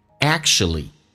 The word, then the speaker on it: actually: You're not，下面一个单词叫 actually。我知道音标叫做 actually，但是在生活口语化当中，不管是英式还是美式发音都会有这样的变化。首先美式发音那个 k 是不用发音的，两个辅音在一起就用发后面一个叫做 act，气流阻塞一下 act，这个发音发成 sh，乌尔发成 a，就变成 actually not actually。